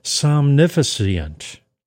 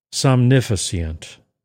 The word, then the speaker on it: Somnifacient: Somnificent, just to get that, the I there that sounds like an E. Yes, a long E. Somnificent. There we go. We got it there.